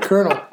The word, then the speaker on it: Colonel: Colonel. – It's spelled way different. – Colonel Sanders.